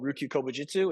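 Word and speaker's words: Ryukyu Kobujutsu: You mentioned something I haven't heard of, Ryuku Kubu Jitsu. Did I say that right? – When I was training under Anderson's Karate Dojo, he did a Kobudo system, which he called Ryukyu Kobu Jitsu, which I guess is just like Ryukyu Kenpo.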